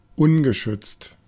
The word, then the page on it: bleak: American English; [blik]